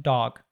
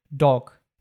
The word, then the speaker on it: dog: For example, an American, most Americans would say the word dog like this with a ah sound, dog. However, Brits, most British people will say dog. Like they say a true O and we say an ah sound. That's not actually an O.